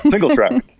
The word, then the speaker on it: single track: Do you pronounce this Singe? – Single track. Just a single track bike. – It's missing a letter.